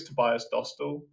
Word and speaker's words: Tobias Dostal: but I think it's Tobias Dostal.